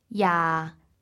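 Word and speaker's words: Ya (廿): Here we have a special character meaning 20, and it's pronounced Ya. Ya.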